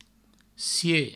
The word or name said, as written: sie